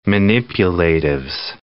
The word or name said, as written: manipulatives